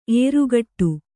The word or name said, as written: ಎರಗಾಡು